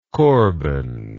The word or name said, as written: korban